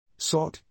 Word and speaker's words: sword: S-V-A-R-R-R-R-D-D-D. – A valiant attempt indeed, but let's employ our syllable skills once more. Say it with me, z-word. – S-word not S-boots. Got it. Those sneaky, silent letters always get me.